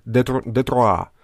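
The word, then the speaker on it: détroit: А, кто-нибудь там попробуйте, гляньте, как в Google Translate, как, как выглядит пролив по-английски, ой, по-французски, а, как пишется, читается как «детруа». Вот.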